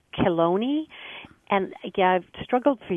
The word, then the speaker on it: Chelone: It's spelled C-H-E-L-O-N-E. And somebody told me to say it like baloney, but with a K in the beginning. So Chelone. – Chelone.